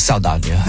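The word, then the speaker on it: Saldaña: I never pronounce her name right. – I think it's Saldana. – I think it is too. Okay. We got it the second time.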